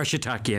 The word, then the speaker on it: shiitake: They are shiitake anyway.